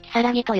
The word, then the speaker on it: 鬼: 実際、日本には鬼と書いてキサラギと読ませる名字も存在するぜ。